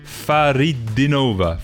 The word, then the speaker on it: Fakhridinova: Sounds like Dinara Faridinova. Faridinova. – Right. – An emphasis on the second I, Faridinova, not Faridinova.